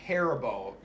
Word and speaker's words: Haribo: It's pronounced Haribo. It literally fucking says Haribo in the Haribo commercials in this country. – Haribo. Haribo. That's how you pronounce it in German.